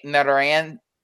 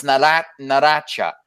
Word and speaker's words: Narancia: So when they say it, it's like, oh, is that how you say it? Narancia? And that's like, no, it's Naracha. I was like, what? How do you not say the C like how we do it in English?